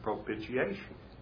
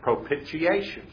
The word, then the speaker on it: propitiation: She said, how you pronounce this? And it was the word propitiation. And I said, "It's propitiation." She said, "See, that's wrong again." She said, "No, it's propitiation. Propitiation, not propitiation."